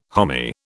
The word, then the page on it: homy: British English